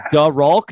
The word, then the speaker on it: Da Rulk: He goes by Da Rulk. Did I say that right? D-A. – Yeah, you did, brother. – R-U-L-K.